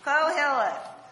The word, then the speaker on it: Koheleth: Koheleth, which refers to someone appointed to stand before the assembly.